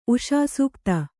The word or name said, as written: ಉಷಾಸೂಕ್ತ